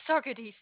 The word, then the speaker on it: Saugerties: It is Sagittarius. It's, what's the zip code? – 12477. – 12477. And did I say it right? Saugerties. – Oh, yes, Saugerties.